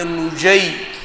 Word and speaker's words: النجي: ومنها حاكم أمثلة عبد الله بن يحيى وهم جماعة عبد الله بن النجي يحيى النجي بضم النون وفتح الجيم وتشديد الياء تابعي معروف يروي عن علي رضي الله عنه.